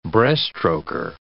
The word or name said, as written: breaststroker